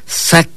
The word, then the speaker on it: sac: El color blanco se pronuncia de esta manera. Escúchelo bien. Ponga usted mucha atención. El color blanco lo pronunciamos de esta manera. Sac. Sac. Escúchelo. ¿Cómo suena? Sac.